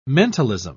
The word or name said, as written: mentalism